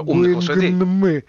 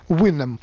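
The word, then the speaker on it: Houyhnhnm: Да, вот мы все старались всегда выговорить как можно старательнее это невыговариваемое слово «гуингнмы». Вот, но на самом деле по-английски-то их, как я сейчас впервые выяснил, нужно произносить ужасно просто. «Уинэм».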